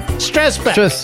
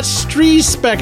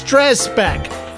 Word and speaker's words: Strzespek: Stressbeck. I've been saying it wrong for all these years, and he finally corrected me. It's not Strzespeck, it's Stressbeck.